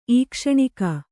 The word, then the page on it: ಈಕ್ಷಣಿಕೆ: īkṣaṇike